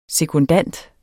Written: sekundant

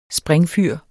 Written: springfyr

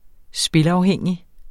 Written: spilafhængig

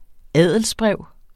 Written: adelsbrev

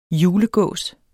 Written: julegås

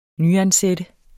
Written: nyansat

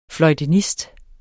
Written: fløjtenist

